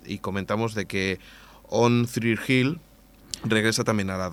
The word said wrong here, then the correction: One Tree Hill: One Tree Hill.